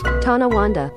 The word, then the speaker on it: Tonawanda: Let me get a pronunciation on this real quick. I apologize for derailing the segment, but I really want to get a pronunciation. Let's see if it's our Pronounceify lady. Here it is. – Tonawanda. – Tonawanda, I got it right.